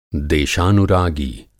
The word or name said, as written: देशानुरागी